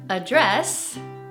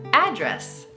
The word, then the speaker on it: address: Y con eso es un poco diferente porque hay dos pronunciaciones. Address es el verbo y address es el sustantivo.